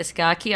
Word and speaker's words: Scacchi: Scacchi, yep.